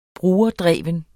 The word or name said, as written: brugerdreven